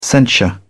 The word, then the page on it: censure: British English